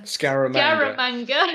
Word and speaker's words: Scaramanga: Scaramanga.